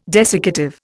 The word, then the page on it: desiccative: Indian